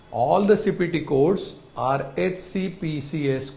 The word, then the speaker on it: HCPCS: It is commonly pronounced as HCPCS.